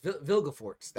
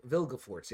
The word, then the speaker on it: Vilgefortz: So his name is called, it's spelled V-I-L-G-E. So that's what I'm thinking, the Vigil. – Oh, it's Vilgefortz. That's how they're saying it. – Okay, and then it's F-O-R-T-Z.